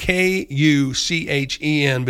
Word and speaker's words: Kuchen: K-U-C-H-E-N, but they give me the pronunciation, and it's a German word for cake.